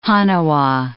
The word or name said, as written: haniwa